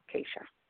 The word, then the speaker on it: Kasha: My name is Keisha, and my birth date is 12/26/76. – And you have to spell that for me, sweetheart. – Huh? Spell it. Spell a name. – Yeah. Please. – K-A-S-H-A. Keisha.